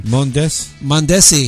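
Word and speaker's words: Mondesí: How did you pronounce his last name? – Mondesi.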